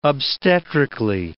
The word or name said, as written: obstetrically